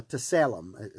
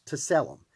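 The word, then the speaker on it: tselem: Now, image here in, in these verses in the Hebrew is the word uh, to, uh, teselem. It's hard to pronounce. To selam. It's T S E L E M in the Hebrew.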